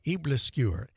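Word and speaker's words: æbleskiver: You probably have no idea what I'm saying because I think until just a few weeks ago, I've been pronouncing this incorrectly because it's not spoken the way it is spelled. Here's the cookbook. It's in front of me. Eblasquer, Eblesquiver, a new take on traditional Danish pancakes.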